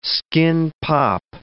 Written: skin-pop

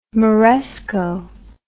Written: moresco

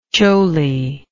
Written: choli

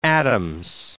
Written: Addams

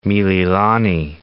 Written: Mililani Town

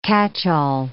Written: catchall

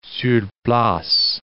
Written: sur place

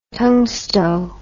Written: tungsto

